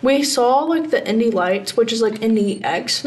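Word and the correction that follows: Indy NXT: now. – Indy Next. – Indy Next, yeah. – They spell it NXT.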